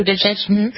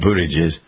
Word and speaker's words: Buttigieg: I liked Pete Buttigieg, whatever, I never pronounced his name right. – Buttigieg. – Yeah, Buttigieg. I liked him as a candidate.